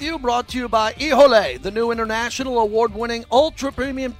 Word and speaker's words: Hijole: Ijole, that's how you say it. Ijole, the official tequila of the show. It's spelled H-I-J-O-L-E.